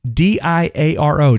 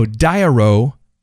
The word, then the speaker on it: Diaro: Of course, I mentioned the day one journal, and then the Android journal is the D I A R O, Diaro app. I believe that's how you pronounce that. Diary, but not a Y on the end, an O on the end.